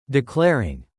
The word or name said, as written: declaring